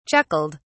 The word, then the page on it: chuckled: British English